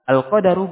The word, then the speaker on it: al-qadaru: Nah, kita baca qala syarih rahimahullahu taala al-qadaru bi fathil. Kata qadar yang mana dalam bacanya dengan memfatha huruf dal.